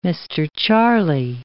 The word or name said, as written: Mr. Charlie